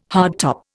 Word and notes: hardtop: Indian

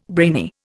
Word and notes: brainy: Indian

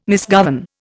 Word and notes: misgovern: Indian

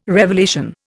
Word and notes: revelation: Indian

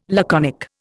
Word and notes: laconic: Indian

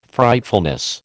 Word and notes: Frightfulness: American English

